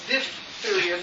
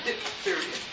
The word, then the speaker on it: diphtheria: And remember, the word is pronounced diphtheria, not diphtheria, like so many unknowledgeable people.